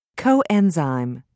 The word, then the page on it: coenzyme: British English